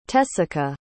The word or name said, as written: tessica